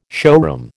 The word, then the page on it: showroom: American English